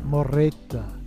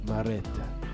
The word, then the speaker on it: Mereta: M-E-R-E-T-A. How do you think you pronounce that? – Maretta. – Maretta. – It's beautiful. – Maretta. Do we put a trill on it?